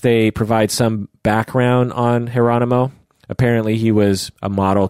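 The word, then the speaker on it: Jeronimo: His name is Geronimo Yanez. Mm-hmm. So think Geronimo, but with a J, pronounced Geronimo.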